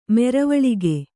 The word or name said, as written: ಮರವಲಗೆ